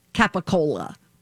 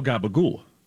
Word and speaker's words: gabagool: Way, is actually Americanized capicola. That's how it's spelled, capicola. – How do you spell gabagool? You don't spell it. – C-A-P-P-I-C-O-L-A.